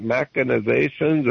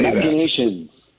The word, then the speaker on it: machinations: This is all political, how do you say, is it machinations or machin? How do you say that? You're a lawyer. How do you say machinations. – Machinations.